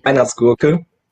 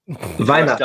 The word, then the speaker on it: Weihnachtsgurke: Yeah, we would pronounce it Weihnachtsgurke. – Bless you. – Can you say that again? – Weihnachtsgurke. – Dumb Americans. – Weihnachtsgurke. – Wein with a V? – Weihnachtsgurke. Weihnacht is Christmas and Gurke is pickle.